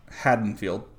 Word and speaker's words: Haddonfield: How would you say it? – Haddonfield, because that's how the characters in the movie say it.